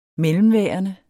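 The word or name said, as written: MELLEMVÆRENDER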